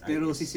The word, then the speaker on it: sclerosis: Amyotrophic lateral, how do you pronounce this? Sclerosis? S-C-L-E-R-O-S-I-S. – Sclerosis yata yan? – Sclerosis. – Yeah. – Okay, Mrs. Thank you for the pronunciation.